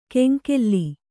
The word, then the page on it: ಕೆಂಕೇಲ್ಲಿ: keŋkēlli